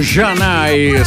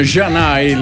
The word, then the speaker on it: Zanè: ricordando il 1994 con Janai, scritto Zanè, che non ha nulla a che fare con la ridente località dell'Alto Vicentino. È scritto Zanè, però insomma Janai, la pronuncia esatta è questa.